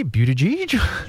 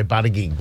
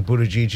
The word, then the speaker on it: Buttigieg: I hope I have pronounced your name properly, Buttigieg. – That's the Maltese pronunciation. Yes. A lot of people say Buttigieg, but it's actually Buttigieg is the correct pronunciation, so well done.